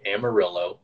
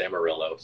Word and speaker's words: Amarillo: Okay, there is a city in the Panhandle named Amarillo, which is, you know, obviously the incorrect pronunciation, but that's what everybody calls it. It's Amarillo, so whatever.